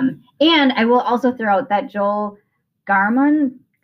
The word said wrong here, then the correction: Gamoran: Gar Gamoran. – Gamoran. Thank you.